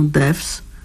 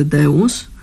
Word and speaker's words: Devs: Jeffa Borowa i The Insects do serialu Devs, a tak naprawdę Deus, ale pisze się Devs.